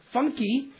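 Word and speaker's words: Funcke: Funky, that's how you pronounce her name, it's F-U-N-C-K-E, of Port St. Lucie, was driving a white Mazda convertible.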